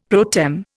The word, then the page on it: pro tem: Indian